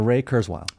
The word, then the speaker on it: Ray Kurzweil: Uh, Ray Kurzweil.